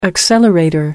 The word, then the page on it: accelerator: American English